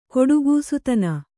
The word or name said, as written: ಘಟಿಕಾಸ್ಥಾನ